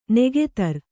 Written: ನಗಧರ

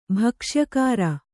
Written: ಭಕ್ಷ್ಯಕಾರ

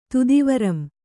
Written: ತುದಿವರಂ

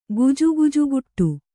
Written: ಗುಜುಗುಜುಗುಟ್ಟು

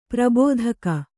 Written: ಪ್ರಾಭಾತಿಕ

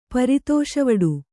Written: ಪರಿತೋಷವಡು

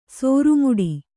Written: ಸೋರುಮುಡಿ

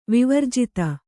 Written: ವಿವರ್ಜಿತ

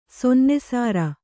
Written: ಸೊನ್ನೆಸಾರ

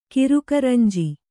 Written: ಕಿರುಕರಂಜಿ

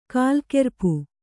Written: ಕಾಲ್ಕೆರ್ಪು

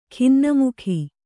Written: ಖಿನ್ನಮುಖಿ

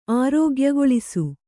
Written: ಆರೋಗ್ಯಗೊಳಿಸು

